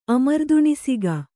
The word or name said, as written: ಅಮರ್ದುಣಿಸಿಗ